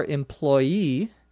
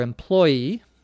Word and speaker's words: employee: I tried to find a store employee or employee, either pronunciation is correct, to ask, but there was no one in sight.